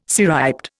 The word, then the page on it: cirriped: Indian